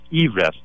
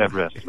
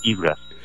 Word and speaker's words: Everest: but his last name was pronounced Everest, so it's only two syllables, and it was not Everest, but Everest.